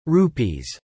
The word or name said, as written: rupees